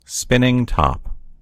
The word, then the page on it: spinning top: American English